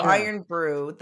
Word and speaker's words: Irn-Bru: Oh, it's uh, Irn-Bru. – It is Iron yeah. Oh. Brew. Yeah. Irn-Bru.